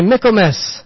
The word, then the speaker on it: Michaelmas: Everybody say Michaelmas. And say it like a Brit.